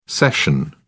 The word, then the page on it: cession: British English